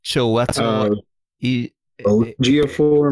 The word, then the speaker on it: Chiwetel Ejiofor: Chiwetel. – Ejiofor, I'm pretty sure. Ejiofor. – Chiwetel Ejiofor.